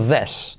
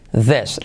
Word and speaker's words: this: No se dice this, sino this. This. La I como una E.